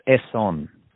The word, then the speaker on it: Essonne: I've just put it into pronunciation. It's Essonne. That's how you pronounce it. – Essonne.